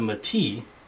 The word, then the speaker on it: Metis: So, from the book, it is the Metis versus Metis. Just in case you didn't know, there's a significant difference between the Metis, phonetically spelled Met-is, and the Metis, phonetically spelled May-T.